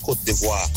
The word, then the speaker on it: Côte d'Ivoire: It's pronounced Côte d'Ivoire.